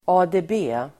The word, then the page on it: ADB: [a:de:b'e:]